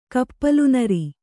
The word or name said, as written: ಕಪ್ಪಲುನರಿ